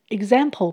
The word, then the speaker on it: example: Example 本身就是指例子。 X 在这里的发音是 gz 的声音，g 会跟前面的字母 e 念成一个，而 z 跟后面的 am 一起念成 zam，也就是 a 在这里是发蝴蝶音，最后的 ple 一起念成 paw，舌头要稍微抬一下。因为有 l 在里面。